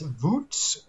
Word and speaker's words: Wootz: because Damascus steel is also known as Wootz, if I pronounce that correctly, Wootz. – That's the way I've heard it pronounced.